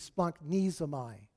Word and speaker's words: splanchnizomai: I mean, S-P-L-A-N-C-H-N-I-Z-O-M-A-I. It's kind of like supercalifragilisticexpialidocious, okay, but in the Greek, okay? But really, it's pronounced splanknesomai.